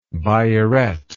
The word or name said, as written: biuret